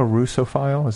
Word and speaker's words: Russophile: So being a Russophile, is that the right pronunciation? – Yeah.